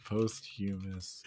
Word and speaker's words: posthumous: Posthumous wrestling. It's generally normally pronounced posthumous. – Posthumous. Okay, sorry.